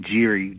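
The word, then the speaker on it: Gerry: We pronounce his name Geary, gerrymandering.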